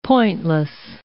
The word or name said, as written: pointless